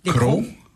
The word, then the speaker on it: Crocs: La vraie prononciation est croc, crocs ou crocs? – Au Québec, ça va être crocs. Ouais. Mais le vrai, c'est crocs.